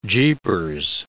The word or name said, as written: jeepers